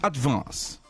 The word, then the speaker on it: advance: É um A puxando pro E, ó. Que vê a diferença, tá. Ó. Advance. Aí você falou advance, mas ó, é um E puxando. Ó, é advance. – Advance.